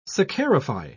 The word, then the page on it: saccharify: British English